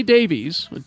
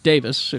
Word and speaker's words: Davies: Ray Davies, Davis, if you're in Britain, Ray Davies, it's spelled Davies, is such a prolific songwriter.